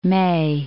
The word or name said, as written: May, Cape